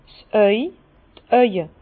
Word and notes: 's Auw, d' Auwe: Bas-Rhin; Herrlisheim

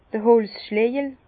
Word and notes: d'r Holzschlejel: Bas-Rhin; Herrlisheim

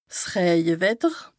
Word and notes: 's Rëjewetter: Bas Rhin; Strasbourg

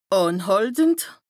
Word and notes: ànhàltend: Bas Rhin; Strasbourg

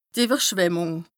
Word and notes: d' Ìwwerschwemmùng: Bas-Rhin; Strasbourg

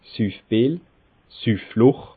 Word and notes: Süffbil, Süffloch: Bas Rhin; Schiltigheim

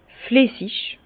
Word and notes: flessig: Bas-Rhin; Herrlisheim

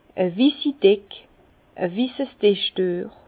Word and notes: e wissi Deck, e wisses Dischdüech: Bas-Rhin; Herrlisheim

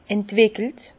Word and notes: entwickelt: Bas-Rhin; Herrlisheim

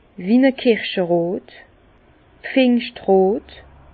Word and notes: wie-n-e kirscherot, Pfingschtros: Bas-Rhin; Herrlisheim

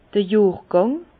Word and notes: d'r Johrgàng: Bas-Rhin; Herrlisheim

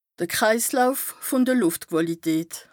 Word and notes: Kreislauf vùn de Lùftquàlität: Bas Rhin

